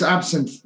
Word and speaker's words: absinthe: In French we say absinthe. I guess the English pronounce it slightly different. – Yeah, it's the same. It's absinthe.